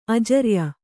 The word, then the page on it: ಅಜರ್ಯ: ajarya